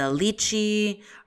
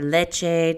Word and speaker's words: lychee: So it has some pineapple, a little bit of the lychee or leche, depending on how you want to pronounce that.